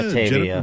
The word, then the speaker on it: Batavia: Uh, she's from, uh, I hope I'm pronouncing this right, Batvia? Batavia? – Batavia. Batavia.